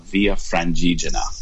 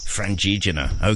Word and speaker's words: Via Francigena: It's mostly pronounced in the Italian version of Via Francigena. – Francigena.